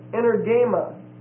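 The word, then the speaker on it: Energema (ἐνέργημα): E-N-E-R-G-E-I-A. Energeia. It's a noun. The second noun is energema. E-N-E-R-G-E-M-A. And the last E is a long E. Energema. Then the verb is energeo, E-N-E-R-G-E-O. And the adjective involved is energes, E-N-E-R-G-E-S.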